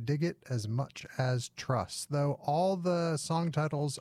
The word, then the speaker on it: TRVSS: that band is from Pittsburgh, and they're called Truss, but it's spelled T-R-V-S-S in all caps. But trust me, or Tervust me, they're called Truss. I asked, because I wanted to know how to say it on the radio.